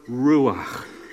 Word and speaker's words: ruach: It's the word ruach. Ruach. It's supposed to sound like what it is.